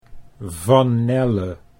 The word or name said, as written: Van Nelle